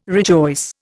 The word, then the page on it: rejoice: Indian